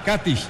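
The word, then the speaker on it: Katić: Con el número 18, central, Nikola Katic. O Katic, ellos le ponen el acento en la A. Katic, Nikola Katic.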